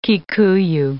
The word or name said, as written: Kikuyu